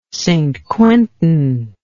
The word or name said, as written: St Quentin